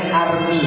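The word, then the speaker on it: Hermès: Bacanya bukan begitu, itu makrosnya salah. Makrosnya salah, berarti gak tahu. Masih pakai barang KW berarti. Bacanya Army, bukan Hermes. Army itu bahasa, bahasanya tulisnya Hermes banyak. Ya itulah bahasa Perancis itu bahasa yang munafik. Tulisan sama bacanya beda.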